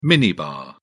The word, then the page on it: minibar: British English